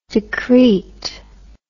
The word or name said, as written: decreet